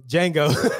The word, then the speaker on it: Django: Because the D is silent, motherfucker. So, Django.